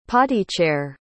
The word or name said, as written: potty-chair